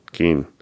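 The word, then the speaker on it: Cian: I want to say it's a hard, C-A-I-A-N. A lot of times in Irish, that's a hard C? – Right, that's what I thought. Kean? – Keen.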